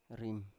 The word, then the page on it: rim: Biak